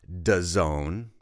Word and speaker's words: DAZN: Some of you may be familiar with the sports television streaming service called DAZN. Well, We pronounce it DAZN, but it's actually the initials D-A-Z-N, D-A-Z-N, which we pronounce as DAZN, which specifically refers to being in the zone.